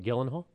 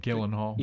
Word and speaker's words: Gyllenhaal: It features the voices of Dennis Quaid, Jake, and I can't pronounce the last name. – Is it Jake Gyllenhaal? – Is that Gyllenhaal? – Yeah, I think so.